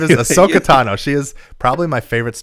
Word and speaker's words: Ahsoka Tano: But there was some incredible Star Wars news yesterday, side tangent, that Ahsoka Tano is going to be, probably going to be in the second season of Mandalorian.